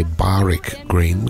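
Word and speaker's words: Barwick Green: And Sydney Torch gave us a Barrack Green, or I should say Barrack Green, because I'm sure that that's the correct pronunciation of that particular word, Barrack.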